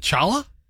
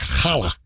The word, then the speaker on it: challah: Is it Chala? – No. – Is it Hala? – Yeah. Technically, it's like Hala.